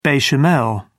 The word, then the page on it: bechamel: British English